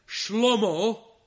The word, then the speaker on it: Shlomo: Solomon, his name is Shlomo. That's how you pronounce it in Hebrew, which sounds an awful lot like Shalom, doesn't it?